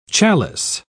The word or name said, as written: chalice